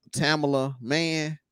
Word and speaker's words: Tamala Mann: and it's Tamala Mann.